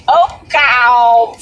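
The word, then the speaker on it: Okawville: Oakville? – No, it said Okaville.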